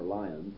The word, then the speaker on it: Lyon: He gathered about him a group of disciples, sometimes called the Poor Men of Lyon, or Lyons, if you give the English pronunciation.